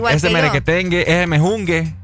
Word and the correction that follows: menjunje: Ah, el menjunje. – Mejunje. El menjunje. No, digo yo. Ah, no, el menjunje he escuchado yo.